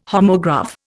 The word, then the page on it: homograph: Indian